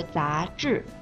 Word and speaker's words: 杂志: 这里面杂技，技的声母是鸡，而杂志，志的声母是之，是卷舌音，发音的时候舌头要卷起来。